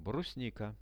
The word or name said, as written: brusnika